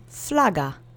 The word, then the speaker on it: flaga: And the last word is flaga, which means flag. The accent is on the first syllable. Repeat after me three times.